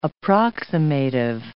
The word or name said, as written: approximative